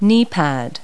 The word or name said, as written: kneepad